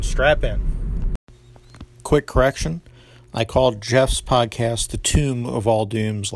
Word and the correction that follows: Tome of All Dooms: last episode. It's Tome of All Dooms, like a book. I knew that when I was saying it, I just mispronounced it.